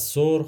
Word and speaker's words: ثور: ما ثور رو سور تلفظ میکنیم اونم به خاطر اینه که توی زبان فارسی برخلاف عربی صدای سه محلی از اعراب نداره و همون سه تلفظ میشه